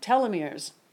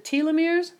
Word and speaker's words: telomeres: Now, I want to talk about telomeres. I've heard people pronounce it telomeres, but I just looked it up and and I found out that it is pronounced telomeres.